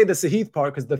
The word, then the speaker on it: Sahith: Sahith. That's how you say it, everybody. I'm sure he's not offended. It's not a big deal, but just FYI, it's Sahith. It's not Sahith.